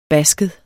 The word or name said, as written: basket